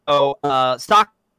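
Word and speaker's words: sake (酒): Sake pronounced sake in Japan, it sake. The E sound is different, but I still say sake because that's how it's fucking pronounced in Japan.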